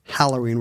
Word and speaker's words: Halloween: All good-hearted people say Halloween with the... – Halloween. – With the short A.